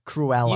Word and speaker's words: Cruella: How do you say it? Cruella. – You had it right and then you doubted yourself.